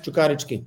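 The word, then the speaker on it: Čukarički: And I've got one more question, and it's relating to the club that you started your managerial career, Cukarici. Is that how you pronounce it? – Yes, Cukarici.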